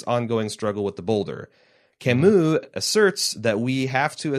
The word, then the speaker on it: Camus: which she helpfully says is pronounced Camus for me, called The Myth of Sisyphus, episode reference number 1, in which he introduced a philosophy of the absurd very briefly.